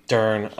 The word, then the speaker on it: Dern: Rhymes with Fern. – Dern.